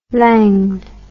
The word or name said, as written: langued